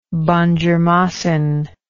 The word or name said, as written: banjermasin